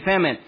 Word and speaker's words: famine: How do you say that? Feminine, yeah. A feminine and that famine. Okay, thank you, famine.